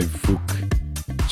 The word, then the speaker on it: Fouk: That was Don't You Want a Boogie by Fook. Such a good name. Because it sounds like, but it isn't. It's spelled F-O-U-K.